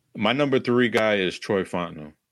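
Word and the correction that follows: Fautanu: Fautano, I believe that's how you say it, from Washington.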